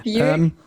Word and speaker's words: Buick: Es ist noch ein kleiner Nebenstrang und die Männer in dem Buick, ich kann Buick nicht aussprechen, in dem Auto. – Buick.